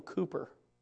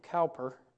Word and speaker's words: Cowper: This is a song by William Cooper. You might pronounce his name Cowper but you find his name in the, in the hymnal.